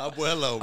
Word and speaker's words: abuelo: It's Bobby Flacco's Ambula. Is that how you say it? – Abuelo, bro.